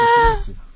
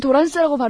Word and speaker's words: 트랜스: 트랜스, 트랜스. – 어쨌든. – 아, 트랜스를 도란스라고 발음하신다고요? – 아, 원래 많이 그렇게 해요.